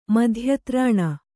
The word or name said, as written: ಮಧ್ಯತ್ರಾಣ